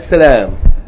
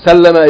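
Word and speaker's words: Sallama (سَلَّمَ): It's not salam. Salam is a noun. Sallama is a verb. Sallallahu alayhi wa sallama. It's a verb.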